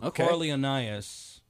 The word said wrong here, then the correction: Coriolanus: I'm not saying that right. It's a Shakespeare play. Coriolanus? – Okay. Coriolanus, yeah.